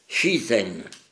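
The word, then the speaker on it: shizen (自然): On emploie des faux « shizen », prononciation japonaise du mot chinois « ziran », introduit au Japon avec le bouddhisme et le taoïsme au 6e et 8e siècle.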